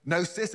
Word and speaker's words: gnosis: The word Gnosticism comes from the Greek word gnosis, again with a silent G, which means to know.